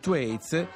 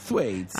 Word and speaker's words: Thwaites: Com'è questo che si pronuncia? Thwaites. – Thwaites. – La TH non così? – Thwaites.